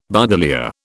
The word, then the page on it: buddleia: British English